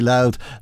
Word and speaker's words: Louth: In fact, perfect pronunciation of County Louth that time round.